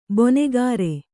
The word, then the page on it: ಬಣಕಾರ: baṇakara